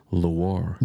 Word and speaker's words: Loire: Is it pronounced Loire? – Loire Valley.